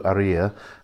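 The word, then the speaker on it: Haría: There's an accent on the I, which is why it's Aria rather than Aria. And the second is that in Spanish you don't pronounce the H, so it's Aria.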